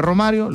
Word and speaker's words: Romario: En el 94 fue Romario, lo dijiste bien tú.